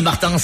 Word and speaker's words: Martins: Alors, mais moi, je tiens à ce que le S se prononce même en français. – Merci, monsieur Martins.